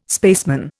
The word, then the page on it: spaceman: Indian